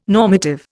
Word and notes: normative: Indian

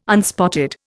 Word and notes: unspotted: Indian

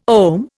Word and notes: OM: Indian

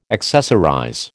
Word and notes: accessorize: American English

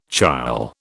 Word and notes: chyle: British English

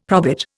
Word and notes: probit: Indian

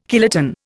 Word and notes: kiloton: Indian

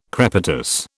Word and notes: crepitus: British English

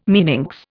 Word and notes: meninx: Indian

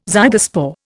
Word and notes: zygospore: Indian

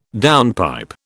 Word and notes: downpipe: British English

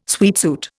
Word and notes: sweatsuit: Indian

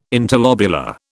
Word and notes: interlobular: British English

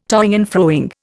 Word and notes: toing and froing: Indian

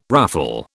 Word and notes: ruffle: British English

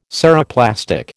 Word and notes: Ceroplastic: American English

